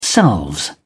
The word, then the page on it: selves: British English